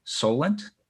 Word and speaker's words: Solent: I'm joined here by one of my favorite clinical researchers in the field, Dr. James Fisher from, I always say this wrong, Solent? – That's correct, yep.